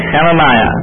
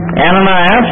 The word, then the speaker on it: Hananiah: And again, this is H-A-N-A-N-I-A, Hananiah. Ananias is the way we pronounce it in English. I don't know really why. I'd rather call him Hananiah because that's more like what the actual name is.